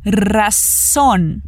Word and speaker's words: razón: O R vibrado, o som de S e o N no final com a pontinha da língua no céu da boca. Razón.